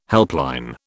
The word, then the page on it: helpline: British English